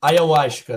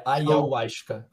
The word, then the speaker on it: ayahuasca: Quero ouvir falar sobre a experiência dele com a ayahuasca, assim pronuncia? – Cara, é ayahuasca. Tá faltando um A ali entre o Y e o U. É ayahuasca.